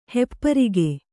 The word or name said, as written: ಹೆಪ್ಪರಿಗೆ